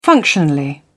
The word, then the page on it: functionally: British English